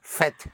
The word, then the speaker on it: faites: So these are the three exceptions for vous, where you have, where you don't have an E-Z, E-Z, but a one-syllable T-E-S, where you sound the T, one syllable for the T. I call them the three QTs. They're very cute, really. Et, fet, and dit.